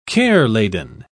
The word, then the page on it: care-laden: British English